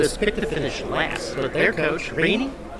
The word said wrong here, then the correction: Rahne: did we pronounce that right? Coach Rainey? – Coach Rainey. – Yeah. – Ronnie. – Ronnie, that's right, like the Rock.